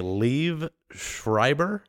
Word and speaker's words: Liev Schreiber: So an even cooler plug is I think Liev Schreiber.